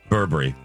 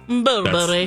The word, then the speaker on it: Burberry: This, yeah, we said you said it right. Burberry. Burberry.